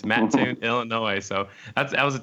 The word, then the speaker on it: Mattoon: So you guys, are in Mattoon? Is that how you say it? Mattoon, Illinois? – Mm-hmm. Mattoon, Illinois, yeah.